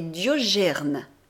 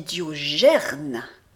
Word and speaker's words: Diogène: Je dis à Rogier qui est là qu'il ne doit pas dire Diogène mais Diogerne. Pas gêne, gerne.